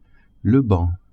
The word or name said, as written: banc